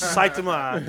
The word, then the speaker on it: Saitama: It's Saitama. That's the way they pronounce it on the show.